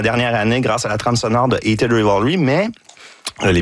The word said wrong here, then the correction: Heated Rivalry: Non, Heated Rivalry.